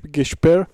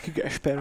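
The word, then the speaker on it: Gaspard: No, podľa mňa najpodstatnejšia vec tohto ročná, len teraz som zabudol dátum, ale vyšiel von release date albumu nového od Gasper. Gashper, či ak sa to po francúzsky vyslovuje. – Gashper.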